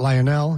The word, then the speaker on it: Lionel: He is not Lionel. He's not Lionel. He's Lionel. That's how you pronounce it.